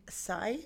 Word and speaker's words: sai: Now you have a two-bladed sai.